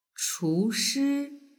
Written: chushi

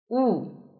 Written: wu

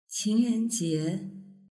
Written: qingrenjie

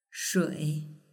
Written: shui

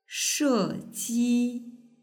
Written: sheji

